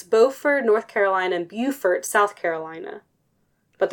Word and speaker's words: Beaufort: It was just, I was like, how to pronounce this? Because I don't want to say it wrong. It's Beaufort.